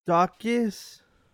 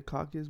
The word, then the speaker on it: Daukaus: So Chris, I used to call him the Caucus, but that's not how you say it.